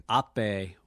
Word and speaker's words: Ape: Ape.